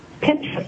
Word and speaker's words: Pinterest: It's Pinterest. Hold on one second. Let me spell it out for you. P-I-N-T-E-R-E-S-T. It's like interest, but Pinterest. – P-I-N-T-E-R-E-S-T.